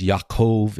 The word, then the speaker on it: Yaakov: Remember the J in Hebrew, you pronounce as a Y. So if you speak English mostly, it's not Jacob, but it's Yaakov. It's not Joseph, but it's Yosef. And then Jesus, of course, would be Yeshua.